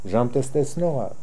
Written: Djamtesternokhi

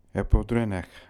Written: Epodrenekh

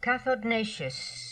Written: Cathodnatious

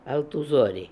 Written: Altoozori

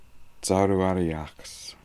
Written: zarooariakhs

